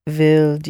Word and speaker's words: wilde: Waarom wilde je? – Now you really must say wilde, wilde. That E has to be pronounced.